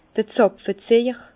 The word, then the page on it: d'r Zàpfeziejer: Bas-Rhin; Herrlisheim